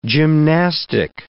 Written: gymnastic

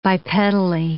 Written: bipedally

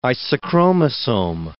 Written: isochromosome

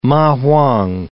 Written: ma huang

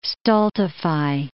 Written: stultify

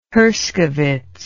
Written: Herskowitz

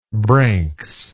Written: branks

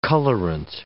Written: colorant

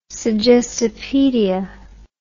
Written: suggestopaedia

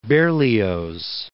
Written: Berlioz

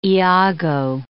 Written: Iago

